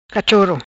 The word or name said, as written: Cachoro